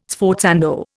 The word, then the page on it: sforzando: Indian